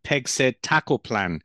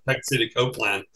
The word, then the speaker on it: pegcetacoplan: I actually like your pronunciation better than the company. They pronounce it Pegcetacoplan. – Okay. – It sounds more like Copay or something.